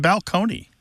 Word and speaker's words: balcony: My favorite change that I love to talk about when Martha and I go and do live events in the rows is the word balcony, place on a stage that you can look down upon the seats, used to be pronounced balcony.